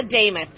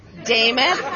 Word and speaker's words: Damuth: And as your next nominee as a Republican for governor, my name is Lisa Damuth. I'm going to give you the easiest way to understand that. It rhymes with Joe Namath. Damuth, Namath.